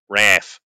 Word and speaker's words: RAAF: if you're Australian, RAF. That's how they say it. It's RAF.